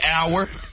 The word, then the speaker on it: hour: The H is silent. – Exactly. H-O-U-R, the H is silent.